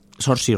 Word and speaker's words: Saoirse: ¿Sorsi? – Saoirse Ronan, sí, sería más...